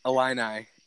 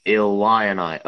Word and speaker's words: Illini: Before the preseason, I just had no hope in the fighting Illini. I don't know how to pronounce it. – Illini. Say it with me. Illini.